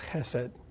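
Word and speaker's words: chesed (חסד): It is a word that in Hebrew is pronounced chesed.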